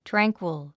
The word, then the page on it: tranquil: trǽŋkwil; トラァンクォ